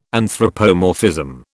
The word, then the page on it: anthropomorphism: British English